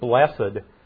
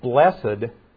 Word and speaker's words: blessed: And just a note on that word blessed, and I pronounce it blessed instead of blessed.